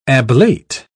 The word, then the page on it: ablate: British English